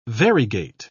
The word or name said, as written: variegate